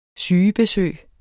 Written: sygebesøg

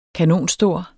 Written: kanonstor

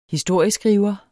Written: historieskriver

